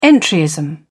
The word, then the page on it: entryism: British English